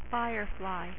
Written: firefly

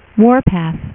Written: Warpath

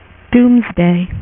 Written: doomsday

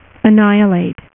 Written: annihilus

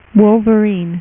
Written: wolverine

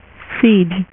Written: Siege

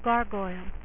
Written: Gargoyles

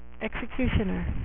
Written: X-Cutioner

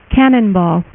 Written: Cannonball

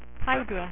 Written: Hydra

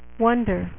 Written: Wonder Woman

